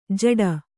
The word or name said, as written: ಝಟ್ಟಿ